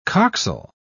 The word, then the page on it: coxal: British English